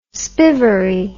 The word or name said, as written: spivvery